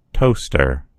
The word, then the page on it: toaster: American English